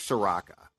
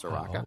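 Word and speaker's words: Ciciarrocco: Well, it could be a harder C, Sriracha? But I don't think it's C-I-C-I-A-R-R-O-C-C-O, so I don't think that there's a hard C there, so I think it's Soraka.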